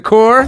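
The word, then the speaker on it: Corps: The core? – It's pronounced core.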